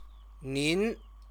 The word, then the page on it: nin: Chinese